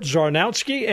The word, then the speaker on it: Czarnowski: And that's an easy name to pronounce and a hard one to spell, but it's Czarnowski. Kurt Zornowski.